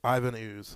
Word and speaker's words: Ivan Ooze: Yvonne. – Ivan Ooze.